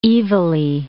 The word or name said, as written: evilly